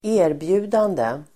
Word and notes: erbjudande: ²'e:rbju:dande el. ²'ä:-

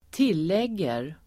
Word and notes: tillägger: [²t'il:eg:er]

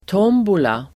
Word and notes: tombola: [t'åm:bola]

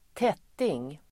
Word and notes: tätting: [²t'et:ing]

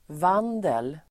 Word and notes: vandel: [v'an:del]

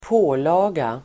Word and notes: pålaga: ²p'å:la:ga

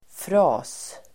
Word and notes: fras: [fra:s]

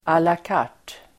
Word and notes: à la carte: Swedish

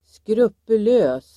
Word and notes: skrupulös: [skrupul'ö:s]

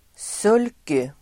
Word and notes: sulky: [s'ul:ky (el. -i)]

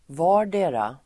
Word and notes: vardera: [v'a:r_de:ra]

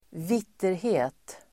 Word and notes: vitterhet: [²v'it:erhe:t]

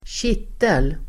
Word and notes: kittel: [²tj'it:el]

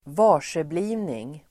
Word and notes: varseblivning: [²v'ar_s:ebli:vning]

